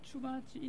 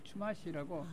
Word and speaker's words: 추마시: 추마치 인디안이 살았어요. 추마시라고. – 아, 추마시 인디안. – 우리말로 발음을 하면은 추마치 좀 되기도 하고 추마시라고 되기도 하는데 그 추마시 인디안이 살았는데 그 인디안들이 원주민들이 원래 살았는데 이 사람들이 많이 살았어요.